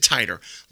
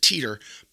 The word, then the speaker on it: Titor: That's right, I said Titor. A lot of people pronounce it Teeter, but I believe the correct pronunciation is Titor, not Tit or Titor. So we're going to be going with John Titor tonight.